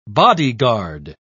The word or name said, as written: bodyguard